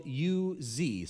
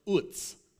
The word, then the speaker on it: Uz: Um, the text tells us that Job was a man from the land of, it's spelled U Z, so you would imagine it's Uz, you know, but it's actually not. It's pronounced Utz, okay?